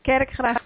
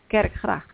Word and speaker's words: Kerkgracht: No, Kerkgracht, it's church, it's like a church, okay. And then there is also water next to it. So that's how you pronounce it in Dutch, Kerkgracht.